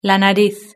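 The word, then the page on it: la nariz: Spanish (Spain)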